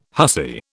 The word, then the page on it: hussy: British English